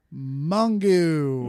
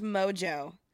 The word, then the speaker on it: Mongeau: Mungu. – No, it's pronounced Mojo. – Get the fuck out of it. M-O-N-G-E-A-U.